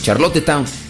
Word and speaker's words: Charlottetown: Charlottetown, ok, esa es la pronunciación. Charlottetown.